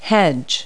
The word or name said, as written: hedge